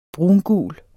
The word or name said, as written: brungul